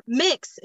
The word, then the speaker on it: Mx: So the mix is how it's pronounced, and mix. Yes, it's an honorific.